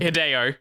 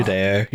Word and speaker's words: Hideo: Hideo. – Oh, fuck. – Hideo, yeah.